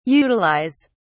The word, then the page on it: utilise: British English